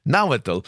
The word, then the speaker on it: Nahuatl: Yeah, it's Nahuatl. Did I say that right? – Nahuatl for cooked agave. – Correct.